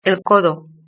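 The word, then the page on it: el codo: European Spanish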